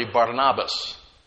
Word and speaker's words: Barnabas: That's how he had actually gotten his name, Barnabas, or as it's pronounced properly, Barnabas, son of consolation, or the encourager.